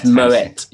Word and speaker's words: Moët: It's Moët, yes. It's like yeah. Wet.